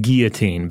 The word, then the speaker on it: guillotine: It's got to be the And Robert, before I say it, do you say it like a French guy's name or like what a fish breathes with? – I go with guillotine because it sounds a little more like an open-faced sandwich that way. And also it has the gi has more of a sound to it.